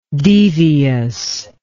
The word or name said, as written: deviousness